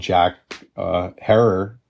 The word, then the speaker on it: Jack Herer: Others love the smell of Jack Herer, rhymes with terror, or train wreck, right?